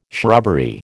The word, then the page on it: shrubbery: American English